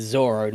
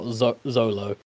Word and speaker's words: Zoro: I believe the official pronunciation of his name is Zoro, not Zolo.